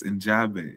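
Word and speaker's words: Njigba: Look at how it's spelled. It's Njabe. It's Njabe. In the phonetics of how that's spelled, the I is going to be like silent there that you're seeing. So it's Njabe.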